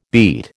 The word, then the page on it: beet: American English